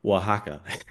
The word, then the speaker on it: Oaxaca: And it's spelled like Oaxaca, but it's pronounced Oaxaca.